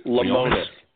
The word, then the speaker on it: Lemonis: The prophet, Marcus Lee Lemonis.